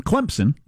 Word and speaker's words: Clemson: And so at least one college football program, Clemson, it's pronounced with a P even though there's not one there.